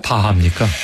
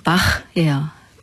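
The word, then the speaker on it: Bach: 헨델, 하이든, 바흐, 바흐입니까? 바하입니까? – 원어로 하면 바흐예요. 바흐.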